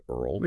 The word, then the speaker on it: IRRL: For them, there's the VA Interest Rate Reduction Refinance Loan, usually called an IRRL, pronounced EARL.